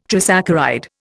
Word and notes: trisaccharide: Indian

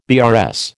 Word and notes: BRS: American English

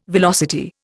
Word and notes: velocity: Indian

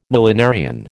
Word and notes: millenarian: American English

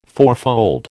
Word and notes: fourfold: American English

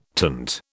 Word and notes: tint: British English

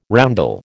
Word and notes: roundel: American English

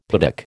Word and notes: piddock: American English

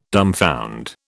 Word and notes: dumbfound: British English

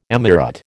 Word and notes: emirate: American English

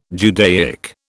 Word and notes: Judaic: British English

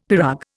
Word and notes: pirogue: Indian

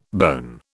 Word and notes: bone: British English

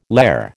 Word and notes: lehr: American English

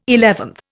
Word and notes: eleventh: Indian

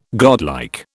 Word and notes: godlike: British English

